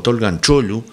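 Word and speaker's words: Tolgahan Çoğulu: Él pronuncia algo así como Tolgan Cholu. Si uno lee cómo se escribe, es Tolgahan Kogulu, pero bueno, él lo debe pronunciar correctamente, seguramente.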